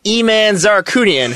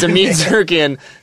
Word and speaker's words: Amin Zarakunian: And I was like, it's like, do you mean Amin? First of all, that's not how you say his name. It's Amin Zarakunian.